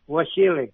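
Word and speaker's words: Vasily: Do you pronounce it Wasily or Wasily? – Wasily. – Oh, okay. That's what I thought.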